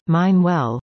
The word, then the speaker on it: MYNWELL: Priya Maloney, a director at MYNWELL, pronounced Mine Well,